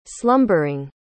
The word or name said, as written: slumbering